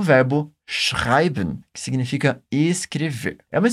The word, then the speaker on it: schreiben: Presta bastante atenção agora nos sons que nós temos na palavra schreiben. Primeiro, temos um som bem forte de sh. Depois, aquele som de R lá da garganta.